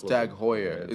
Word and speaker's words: Tag Heuer: I know a watch seller who sells like the there's a Tag Heuer. – Tag Heuer. – It's Tag Heuer. It's like, because I imagine it's pronounced the same as that goalkeeper. – Neuer. – Oh. Same spelling, right?